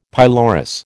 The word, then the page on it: pylorus: American English